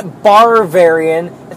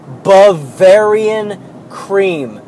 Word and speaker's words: Bavarian: And fuck, people cannot pronounce Bavarian. No R. There is no R in the word Bavarian. Okay, there is. There is one R in the word Bavarian. It's not Barvarian. It's not Bavarian. It's not like Barvanin. It's Bavarian cream.